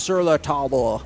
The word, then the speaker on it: Sur La Table: And everybody told me, no, that's wrong. It's pronounced Sur La Table.